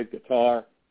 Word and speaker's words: Qatar: Qatar. Why? Or gutter, as it's sometimes pronounced.